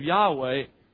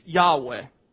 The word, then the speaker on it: Yahweh: And we're not positive of the pronunciation, but we believe it's Yahweh.